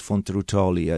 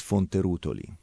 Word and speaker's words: Fonterutoli: So, the name of our winery is Castello di Fonterutoli, which the Americans always pronounce Fonterutoli. I don't know why, but the real pronunciation is Fonterutoli.